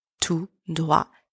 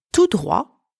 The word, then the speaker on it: tout droit: There is a difference between tout droit, which means straight ahead, that we used in our first expression, where the final T is silent, and à droite, where you hear the T. It's not silent. Tout droit, à droite. Tout droit, T silent, à droite, T pronounced.